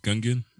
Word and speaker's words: Gungeon: And then on March 17th, Exit the Gungeon. Did I say that right? – Yeah, because it's like Dungeon, but it's Gungeon.